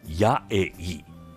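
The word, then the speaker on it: 8A: 家焙煎スペシャリティコーヒーが楽しめる八重井ガレージコーヒー店長の大和真一郎さんにお話をお伺いします。大和さん、よろしくお願いします。 – よろしくお願いします。 – カフェのお名前、八重井ガレージコーヒー、これ数字の 8 にアルファベットの A と書いて八重井と読む、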